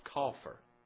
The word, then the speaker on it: kaphar: It's the Hebrew word, and it's spelled K-A-P-H-A-R, and it's actually pronounced coffer. Coffer.